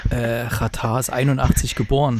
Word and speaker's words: Xatar: Ja, aber, da, aber da steht Xatar, was du gerade gesagt hast. Ja, der spricht sich aber Ratar. Ach so, ja. – Ähm, ah, das übrigens, ist kurdisch.